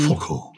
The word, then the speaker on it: fauchle: but it's spelled F-A-U-C-H-L-E. So Faucle or Fochle. – F-A-U-C-H-L-E. Faucle. Yeah. – Yes. – Say it carefully. – And it's from the Scots.